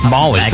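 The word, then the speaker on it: Magli: Molly. – Bruno Molly. – Molly. So the G, so the G is silent? – Yes.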